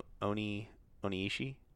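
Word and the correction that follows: O-Ren Ishii: Oren Ishii.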